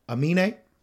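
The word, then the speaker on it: Aminé: But I wanted more from Amine.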